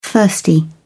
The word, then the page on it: thirsty: British English